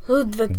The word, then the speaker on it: Ludwig: It's the the W is always pronounced V in like Ludwig.